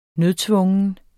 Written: nødtvungen

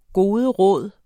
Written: goderåd

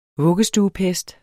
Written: vuggestuepest

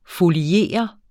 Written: foliere

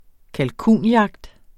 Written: kalkunjagt

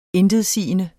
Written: intetsigende

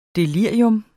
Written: delirium

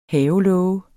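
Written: HAVELÅGER

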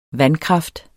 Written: vandkraft

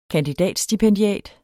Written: KANDIDATSTIPENDIATEN

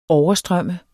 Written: overstrømme

